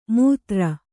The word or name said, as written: ಮುತ್ತರ